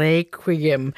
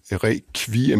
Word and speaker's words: requiem: og vi ruller på æret og synger requiem med den latinske ku-udtale. Og samme italienske udtale bruger man altså også i de engelsktalende lande. – Men det gjorde man altså ikke på Mozarts tid i Wien, siger Rolf. Der har koret ikke udtalt ordet på den måde. For sproget i Wien, det er jo tysk, og den tyske tradition for latinudtale, den er meget markant og anderledes. Der siger man requiem med kv, ligesom på dansk, og der er mange andre lyde, der bliver udtalt anderledes.